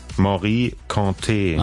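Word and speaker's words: Mory Kanté: Wie wird's ausgesprochen? – Morikante. – Ach so, guck mal. Das war also falsch.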